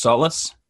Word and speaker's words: Sola: Remind me, your last name is pronounced Solas? – No, Sola. – Sola. – S-O-L-A.